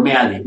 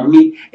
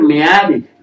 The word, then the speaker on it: Meade: Si se piensa dedicar el voto a este personaje, es por, por Meade, no es Smith. – No es Smith. No he encontrado una sola regla dentro del idioma español que nos diga que eso tiene que ser pronunciado como mit, Eso es mentira. – Se debe leer Meade.